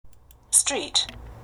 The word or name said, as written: street